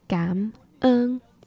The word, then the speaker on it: cảm ơn: Cảm ơn, C-A-M. Although it's a very soft C, it's not like a k, it's like a g. So cảm. And then ơn is O-N without the tone. So it sounds more of like a U.